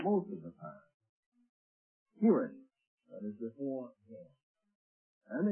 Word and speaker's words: Cherith: Almost all words in the Bible that begin with a CH are pronounced like a K. In other words, don't hesitate. Don't say, just say Kirith.